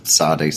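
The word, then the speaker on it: Tsade: Yeah, so we're looking at Psalm 119, verses 137 through 144, which is the section Zadeh, if I'm... Is that how to say it? – Yeah. – Zadeh, like Zadeh. – That? Okay. Kind of a TS or TZ sound.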